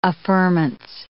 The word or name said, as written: affirmance